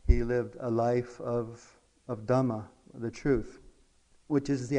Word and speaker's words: Dhamma: Refuge in the Dhamma is, how we pronounce it in the Theravada tradition, which is what we practice here. In the Sanskrit, Mahayana, we call it Dharma.